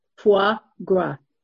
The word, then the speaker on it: foie gras: faux gras, whatever it is, the stuffed ducks. What was it? What was it? – Foie gras.